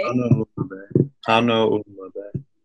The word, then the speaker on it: Hanauma Bay: Hanauma Bay. – Hanauma Bay.